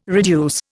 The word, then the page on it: reduce: Indian